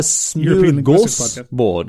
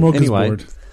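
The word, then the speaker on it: smörgåsbord: Welcome to our northern and it's actually a smooth gorse board because the A with a little O above it makes an O sound.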